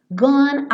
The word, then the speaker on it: gone: Então, gone are. Ah, mas o gone termina com E, mas o E não é pronunciado. Então, o som que termina gone é N, é consoante.